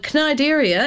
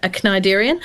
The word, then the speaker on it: Cnidaria: And Cnidaria, and I actually had to Google this pronunciation of this, I would have said Cnidaria, C-N-I-D-A-R-I-A.